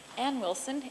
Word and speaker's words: Anne Wilson: Ann Wilson, A-N-N-E-W-I-L-S-O-N.